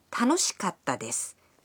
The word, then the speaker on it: 楽しかった: In this way, it's fun, 楽しいです, for example, becomes 楽しかったです, meaning it was fun. – Listen and repeat. Now, katta is pronounced with a brief pause in between ka and ta.